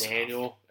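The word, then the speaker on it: Danuel: It's D-A-N-U-E-L instead of I-E-L. – Yeah. – But I think it's pronounced close Daniel. Enough. If not, it's Daniel with a little pizzazz to it.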